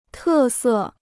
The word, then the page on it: 特色: tè sè